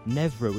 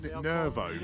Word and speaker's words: Nervo: Then on the 30th of October, Nevro at Digital in Newcastle, we have two lovely lady DJs, with no names. – Or is that their name? – Nervo.